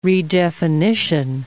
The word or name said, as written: redefinition